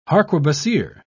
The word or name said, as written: harquebusier